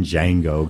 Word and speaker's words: Django: The D is silent. – Yeah. When they got to a scene in Django, excuse me.